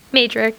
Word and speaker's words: Mejdrich: Mejdrich.